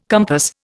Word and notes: compass: Indian

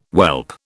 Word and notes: whelp: British English

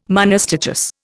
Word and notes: monostichous: Indian

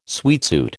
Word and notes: sweatsuit: American English

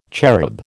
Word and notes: cherub: American English

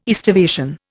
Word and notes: aestivation: Indian

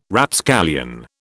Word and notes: Rapscallion: British English

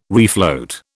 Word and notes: refloat: British English

